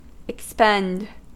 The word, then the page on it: expend: American English